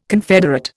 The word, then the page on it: confederate: Indian